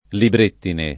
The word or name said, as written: librettine